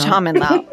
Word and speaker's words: Chamanlal: Chamanlal.